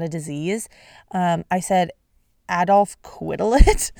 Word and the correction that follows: Quetelet: which so uncultured of me. His name is, and I'm not going to try to pronounce it in the French way because I will not do justice. I will make a fool out of myself. So I'm going to pronounce it the way that it's at least Google or Wikipedia was telling me it's pronounced, without the accent. It's Adolphe Quetelet. Quetelet.